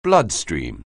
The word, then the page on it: bloodstream: anglais